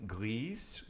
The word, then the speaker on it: grise: And that is gris, and of course the feminine form would be grise, you'd pronounce that S.